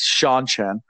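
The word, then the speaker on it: Seanchan: So that's how you pronounce it. It's Sean Chan.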